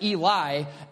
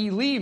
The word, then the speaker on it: Ely: I pronounced it Eli, and was promptly corrected by a native Minnesotan that I did that incorrectly. So they're going to be going to Ely, Minnesota.